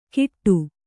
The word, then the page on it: ಗಿಟ್ಟು: kannada